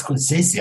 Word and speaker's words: Scorsese: He says, you know, remember that guy Scorsese? He couldn't remember his name. Scorsese. Yeah, I said, no, it's Scorsese.